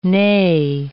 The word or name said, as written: nay